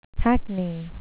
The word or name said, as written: hackney